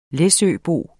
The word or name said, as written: læsøbo